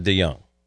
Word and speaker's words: DeJong: It looks like DeJong. Why isn't it DeJong? – Because he says it DeJong. It is a J, but it's DeJong.